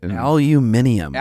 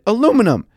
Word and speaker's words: aluminum: Aluminium, which is not how the word is pronounced. Well, it's actually spelled differently in England. Because they're because that's ugh, I did they even- change it or do we change it? no when when the element was discovered, the guy that discovered it spelled it aluminum and pronounced it aluminum some jackass along the way screwed it up somewhere, and everyone was just like, "Let's go with it."